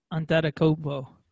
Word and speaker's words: Antetokounmpo: Antetokounmpo? – It's Giannis like Yanni. So it's Giannis Antetokounmpo.